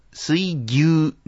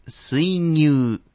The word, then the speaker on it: 水牛: ここは少々難しいところですが、水牛ではなくて水牛、漁業ではなくて漁業と発音します。